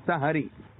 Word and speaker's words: سحری: تو صحیح اس کا تلفظ ہے سحری.